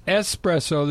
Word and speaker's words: espresso: And he said, "No, it's espresso. There's no X in espresso."